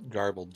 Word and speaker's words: Garbled: Garbled, G-A-R-B-L-E-D.